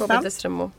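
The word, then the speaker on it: Desramaults: chambre separé, Kobe Destremo, hur man uttalar Kobe Destremo.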